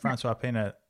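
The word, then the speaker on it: François Pienaar: François Pina at the Rugby World Cup final 95.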